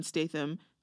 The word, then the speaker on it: Statham: Huge shout out to my brother, Jason Statham. Is that how you say his name? – Yeah.